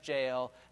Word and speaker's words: Jael: And the word Jael rhymes with nail, and it's easy to remember the story this way, how that Sisera is running from the battle.